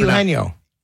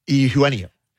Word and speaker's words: Eugenio: Eugenio. – Yes, I can't. I struggle. Eugenio. – Yes. – Eugenio.